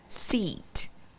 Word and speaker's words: seat: Trong tiếng Việt chúng ta có chữ là sờ y tờ sắc đọc là sit, sit. Tuy nhiên trong tiếng Anh động từ ngồi đọc là E, sit, sit và chữ chỗ ngồi danh từ đọc là seat, seat.